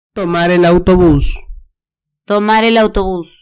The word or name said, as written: tomar el autobus